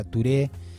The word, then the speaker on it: Touré: Se llamaba Ali Farka Touré, o Ali Farka Touré, se pronuncia Touré.